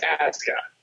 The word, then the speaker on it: Asuka: Okay. Japanese pronunciation. WWE is doing one thing right, because Japanese pronunciation, you never pronounce the U. Okay. Mm. But it's Asuka.